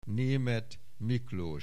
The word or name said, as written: Németh Miklós